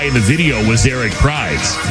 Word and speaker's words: Prydz: Is it Eric Prydz? – Yeah. It's Prydz, yeah. – P-R-Y-D-S. – I've heard it said both.